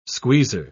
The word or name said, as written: squeezer